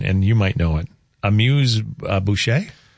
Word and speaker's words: amuse-bouche: Amuse-bouche. – Amuse-bouche.